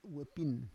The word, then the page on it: we pin: Roon